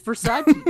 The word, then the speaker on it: Versace: It's Versace, dick. – But he wasn't saying you fucking idiot. He's like, it's pronounced Versace.